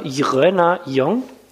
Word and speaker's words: Jröner Jong: Und man schreibt das J-R-Ö-N-E-R neues Wort Jong, J-O-N-G. Also ich sage immer Jröner Jong und finde, das entlarvt immer jeden, der nicht in Düsseldorf geboren ist, weil man kann es irgendwie als Nicht-Düsseldorfer nicht richtig aussprechen.